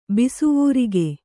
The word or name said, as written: ಬಿಸುವೂರಿಗೆ